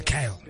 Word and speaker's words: KLY: It's like K-L-Y. Like. – Yeah, Kyle. Kyle. – But you don't spell Kyle, K-L-Y. – That's how they spell it and pronounce it.